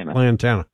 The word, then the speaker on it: Lantana: Is that the right pronunciation? Lantana. – Lantana. Yeah.